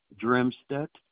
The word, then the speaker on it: Dremstedt: Glenn Dremstedt.